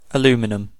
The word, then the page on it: aluminum: American English; /ə.ˈlu.mɪ̆.ˌnə̆m/